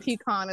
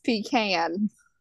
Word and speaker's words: pecan: In the South, it's not pecan, it's pecan.